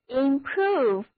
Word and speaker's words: improve: 那么后面再加上一个 e 动词后缀，所以单词 improve 就是表示使谁获得益处、改进、使什么东西更好。 – improve，字母 o 发 u 的长音，单词就读作 improve。